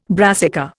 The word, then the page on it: brassica: Indian